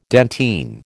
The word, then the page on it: dentine: American English